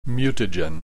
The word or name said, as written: mutagen